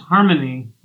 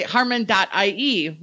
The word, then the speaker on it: Harmon.ie: It's pronounced Harmony, like, you know, we sing together in harmony. – Cool. I always say Harmon.ie.